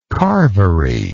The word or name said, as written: carvery